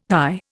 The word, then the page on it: thy: Indian